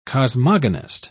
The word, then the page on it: cosmogonist: British English